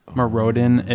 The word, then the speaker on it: Mirrodin: Mirrodin, if you don't know how to pronounce that.